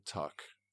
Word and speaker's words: Tuck: Like when I played in the States in college, obviously I have the last name Tuck, T-U-C-K.